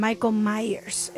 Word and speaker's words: Michael Myers: Perdón, Michael Myers es la pronunciación correcta.